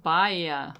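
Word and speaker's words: Baia: Now, here's a guy who has a bunch of restaurants, Matthew Kenny, and he just opened one in San Francisco called Bahia.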